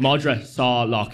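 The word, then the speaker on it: Madra Salach: Sorry, it's Madra Salak.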